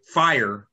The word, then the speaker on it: Fyre: A judge has ordered in a class action lawsuit hundreds of fire, F-I-R-E, but it's spelled F-Y-R-E, fire festival ticket holders.